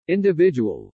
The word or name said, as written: individual